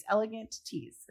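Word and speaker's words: Elegantees: And even 6 years ago, there's like Elegantes. I never know if I said that right. It's Elegant Tees, the company.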